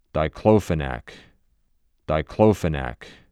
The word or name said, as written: Diclofenac